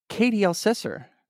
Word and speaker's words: Katie Elsesser: We're going to talk about video games with the absolutely fabulous Katie Elsesser.